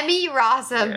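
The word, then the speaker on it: Emmy Rossum: You have said her name wrong at every turn. – Have I? What is it? – Amy Rossum. Yeah.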